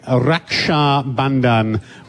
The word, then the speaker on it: Raksha Bandhan: We both have great sisters, and we celebrate them today with Raksha Bandhan, which is amazing.